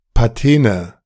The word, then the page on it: Patene: [paˈteːnə]